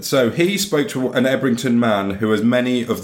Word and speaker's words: Ebrington: You are, however, you wouldn't be able to tell that by reading it because it's a town called Ebrington. – Ebrington? – Ebrington, Ebrington, in Gloucestershire. Locally, it's pronounced Yubberton.